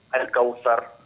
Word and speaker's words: الكوثر: لا لا لا، في حرف الثاء، قل مثلي بارك الله فيك، قل حرف الثاء، انطق الثاء، الكوثر. – نعم. الكوثر، ايوه هكذا، ايوه تمام، لا تقول الكوثر، خليها الكوثر دائما.